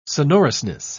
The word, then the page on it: sonorousness: British English